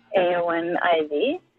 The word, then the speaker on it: Eowyn Ivey: Yes, I am reading the second novel of her series, and it's Eowyn Ivey. I think I'm saying her name correctly. – You certainly are.